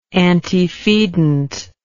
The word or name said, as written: antifeedant